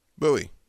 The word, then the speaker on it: Buoy: Buoy. Like those things that float in the ocean.